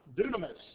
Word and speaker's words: dunamis: There's one that is pronounced dunamis, and you'll find that in Romans 1, verse 16, the power of the gospel.